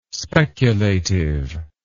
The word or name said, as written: speculative